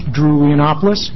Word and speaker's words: Drew Leonopoulos: For the record, my name is Drew Leonopoulos, rhymes with Minneapolis.